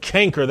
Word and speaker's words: canker: See, that word canker, that's the old English word for cancer. You just take the K and make it a soft C.